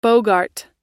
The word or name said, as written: bogart